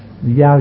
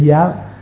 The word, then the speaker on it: giao: Nhân sự đề cứu lan tự, đức bạc thường tàm kế thổ tổ đăng, không giao, giao chứ không phải giáo, không giao, tuy chữ giáo mà mình đọc là giao, không giao hàng thật nổi quan tăng, tranh như trục bạn quy sơn khứ, điệp chướng trùng sơn vạn vạn tầng.